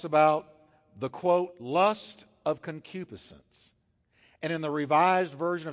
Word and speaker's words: concupiscence: Here's one, concupiscence. I've heard that called all kinds of things. Concupiscence is the pronunciation.